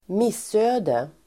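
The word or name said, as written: missöde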